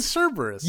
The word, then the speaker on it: Cerberus: Like, yeah, Cerberus. The dog. – Cerberus? – Cerberus. – So, all right, say Halloween Cerberus. – Halloween Cerberus. – You bitch, you said it right that time.